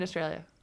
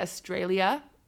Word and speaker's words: Australia: Like, Americans say Australia, and Australians, like, they drop the last A or something like that.